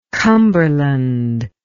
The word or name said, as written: cumberland